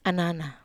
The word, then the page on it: anana: Wamesa